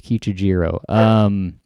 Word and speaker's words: Kichijiro: This character is in the Kichiro. I don't know if I'm saying it right. – Right. – Kichijiro? – Yeah. – I think it's Kichijiro. It's interesting.